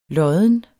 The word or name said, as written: lodden